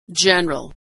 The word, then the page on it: general: dzh